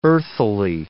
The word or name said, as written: earthily